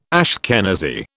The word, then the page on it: Ashkenazi: British English